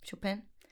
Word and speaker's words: Chopin: Chopin.